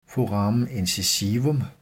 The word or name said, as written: foramen incisivum